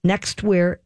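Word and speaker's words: NXTWEAR: But they're called Nextwear, but it's spelled NXT, Nextwear G.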